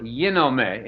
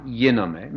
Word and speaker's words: ginomai: Yenao in Greek, G-E-N-N-A-O. And there's another word, yinome, in modern Greek pronunciation, G-I-N-O-M-A-I, yinome, means to become, to come into existence.